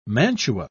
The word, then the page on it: mantua: British English